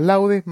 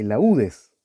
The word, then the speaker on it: laudes: Entonces no es lo mismo los laúdes que las laudes.